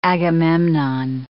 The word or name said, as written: Agamemnon